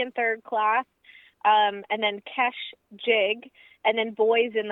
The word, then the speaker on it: Kesh: We're speaking with the band, and we just heard Kesh the Boat, and I'm not mispronouncing that, by the way. It is K-E-S-H, Kesh the Boat